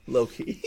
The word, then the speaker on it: Loki: Show's pronounced Loki. Yes. – Loki. – Sorry, am I talking Loki? – Yes, you are. Yes, you are correct.